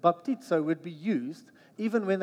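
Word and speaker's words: baptizo (βαπτίζω): Maybe you just say to the person next to you, baptizo. Emphasis on the right, sound like a Greek person.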